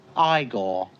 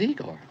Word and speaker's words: Igor: No, it's pronounced Igor. But they told me it was Igor.